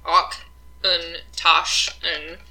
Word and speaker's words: Auchentoshan: We are drinking, first up, is Auchentoshan, which I made sure I checked a pronunciation guide. There's a little cute little video from this distillery about this. So this is Auchentoshan, Auchentoshan, as the pronunciation guide has told me.